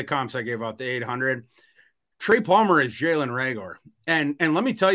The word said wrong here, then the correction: Reagor: you, someone who saw plenty of, uh, well, Reagor, I guess um, I, I, I, always pronounce a Rager when he was at TCU. It's hard to break.